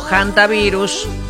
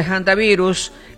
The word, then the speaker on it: hantavirus: Bueno, ustedes sabrán que yo les comenté desde que arrancamos la semana el tema del hantavirus o hantavirus. La H no se pronuncia en inglés, el hantavirus, hantavirus se escribe con H, hantavirus.